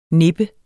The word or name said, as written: nip